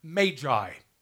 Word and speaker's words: Magi: Actually, it's pronounced Magi. Magi is a plural word for Magus.